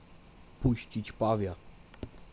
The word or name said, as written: puscic pawia